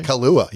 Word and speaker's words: Kaluuya: Yeah, Kaluuya.